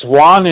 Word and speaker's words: Juan: Well, it's Juan in Spanish, but it's John in English.